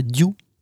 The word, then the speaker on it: Diou: Et je n'ai jamais visité Diou. – Eh ben écoute, ça s'écrit comme ça se prononce, hein, D-I-O-U.